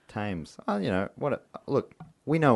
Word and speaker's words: Thames: I'm going to go drown myself in the Thames. – I fucked up saying it wrong. – That's what I'm... Oh. – Thames. God damn it.